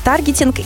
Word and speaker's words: таргетинг: Таргетинг, потому что от английского слова ударение на «а», на первый слог, поэтому однозначно таргетинг. Да, ретаргетинг и все вот это вот на «а».